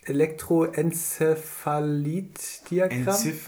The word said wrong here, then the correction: Elektroenzephalogramm: Enzephalogramm. Elektroenzephalogramm.